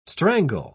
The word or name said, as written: strangle